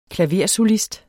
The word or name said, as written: klaversolist